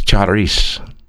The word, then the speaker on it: charis: The correct pronunciation of the Greek word grace is charis, C-H-A-R-I-S, charis, C-H-A-R-I-S, translated grace.